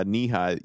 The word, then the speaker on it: Nehi: N-E-H-I? – N-E-H-I, yeah, Knee-High.